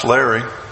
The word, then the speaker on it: Lari: Spelled L-A-R-I, but spelled, pronounced Larry.